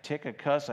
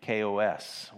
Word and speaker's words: Tychicus: It looks like Tychicus. I think some of us might say it that way. But in the Greek, it's Tychicus with the accent on the last cos. So Tychicus, if I could say it that way, I'm not sure if I'm saying it rightly.